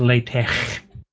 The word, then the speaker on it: LaTeX: The last X is not an X, it's a chi symbol. So it's pronounced LaTeX.